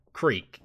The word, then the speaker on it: creek: But it's not spelled like that. – But you say it like that. They're both spelled creek, C-R-E-E-K, but a creek is wider than a crick, which is wider than a stream.